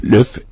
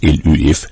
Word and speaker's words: Luv': Løf, L-U-V, som det staves, men det udtales nærmest L-Ø-F, Løf, var ingen undtagelse.